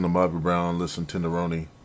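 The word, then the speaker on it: Ronny: I guess it is pronounced Roni. We keep calling him Ronnie, but...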